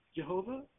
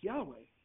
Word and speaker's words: Yahweh: So it's not Jehovah. It's the closest, it's YHWH. We don't know how it was pronounced. But Jehovah? It's not. It's pronounced Yahweh. That's the correct pronunciation.